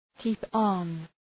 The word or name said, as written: keep on